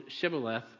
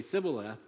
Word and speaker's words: Shibboleth: And they could not pronounce the word Shibboleth properly, and they would say Sibboleth, and then they would be slain. And so that word, that sh sound, or that th sound, is the same letter that we're looking at.